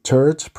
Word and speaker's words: TERT: However, among other things, it's important, for example, a so-called TERT promoter mutation, which is spelled T-E-R-T.